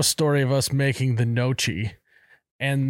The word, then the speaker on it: gnocchi: It's spelled G-N-O-C. – Gnocchi. – Gnocchi. – Yeah. Mm-hmm. Gnocchi. I didn't know how to pronounce it.